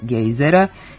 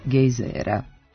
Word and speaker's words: gejzera: U promeni pravilni su oblici sa kratko uzlaznim akcentom na prvom slogu gejzira, to jest gejzera, i sa dugo uzlaznim na drugom slogu gejzira, to jest gejzera.